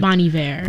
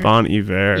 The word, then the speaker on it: Bon Iver: Saying that, they say that right? I always get confused. – Bon Iver. – Bon Iver. – Yeah, it's like the French sort of.